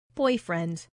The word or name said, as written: boyfriend